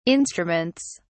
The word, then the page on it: instruments: British English